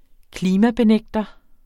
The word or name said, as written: klimabenægter